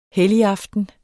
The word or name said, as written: helligaften